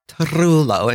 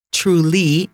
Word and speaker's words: trullo: Puglia has always been known for its olive trees and the trullo, trullo, as you really, if you can roll your R, and trulli is plural.